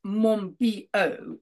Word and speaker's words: Monbiot: So it is pronounced Monbiot, is it? – That's right, yes.